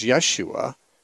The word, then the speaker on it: Yeshua: Jesus is really the same as Yeshua, the difference being that there's an SH in Yeshua because the Hebrew language had SH. The Greek language does not have the SH sound, so it's just the S sound. Jesus or Yeshua would be more closely similar.